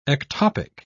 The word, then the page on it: ectopic: British English